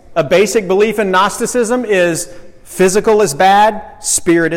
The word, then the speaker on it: Gnosticism: The G is silent. Gnosticism. But I like it that way. It's kind of like the word gnu.